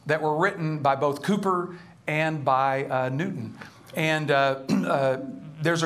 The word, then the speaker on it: Cowper: They pronounce it Cooper in England.